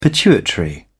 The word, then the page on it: pituitary gland: British English